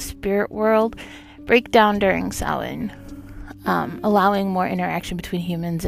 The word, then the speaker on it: Samhain: It is spelled funny because it is a Celtic word, Samhain.